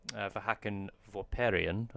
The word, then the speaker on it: Vahakn Vorperian: So, yeah, this week on the show, we have an amazing retoucher that we're bringing you, Vahakn Vorperian.